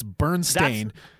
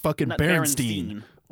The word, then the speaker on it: Berenstain: It's Stain, Stain. – Yeah. – It's Berenstain, not Berenstain. Or Stein, or whatever, however you pronounce it.